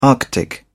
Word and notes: arctic: British English

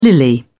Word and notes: lily: British English

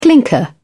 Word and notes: clinker: British English

